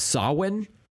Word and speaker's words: Samhain: And then Loomis says, oh, it's Samhain. But like, I've heard it pronounced. It is pronounced Samhain.